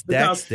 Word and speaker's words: Dak: But I have to say hi to, they go say, I'm going to say this name wrong, Zach Prescott dad, Mr. Nathaniel Prescott. – Yes, Dak's dad, yeah.